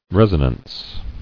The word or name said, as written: Resonance